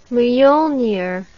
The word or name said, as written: miolnir